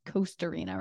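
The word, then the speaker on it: Kostarina: It's like a C, but they spell it with a K. So I pronounce it wrong sometimes. But yes, it's Costarina, right? – Correct. Yes.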